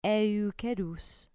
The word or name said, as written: eukerus